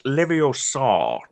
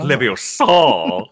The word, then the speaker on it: Leviosa: Mate, you get to make sure that you're pronouncing it just right because it's Leviosa, not Leviosa.